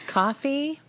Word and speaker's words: Ko-fi: Coffee? – I think it's technically coffee, I think, but it's spelled like Ko-fi, or at least that's the way I always read it.